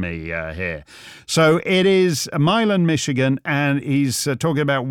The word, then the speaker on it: Milan: He says it's pronounced Milan because he thinks I'm an effete foreign Nancy boy who's going to say Milan, Michigan.